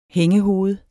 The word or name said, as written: hængehoved